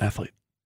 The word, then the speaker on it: athlete: Why do you put three syllables in athlete?